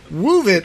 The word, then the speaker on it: Woovit: You gotta add the, the emphasis at the beginning. Woovit.